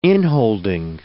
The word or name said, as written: inholding